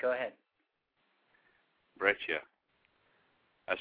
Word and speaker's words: breccia: It's a breccia.